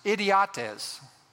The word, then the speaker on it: idiotes: It's interesting, the Greek word translated ordinary there is a word pronounced idiotes.